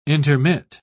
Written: intermit

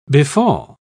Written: befall

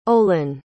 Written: Olin

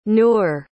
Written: nur

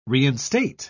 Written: reinstate

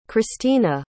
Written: christeena

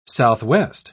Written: southwest

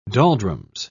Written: doldrums

